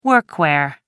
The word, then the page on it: workwear: American English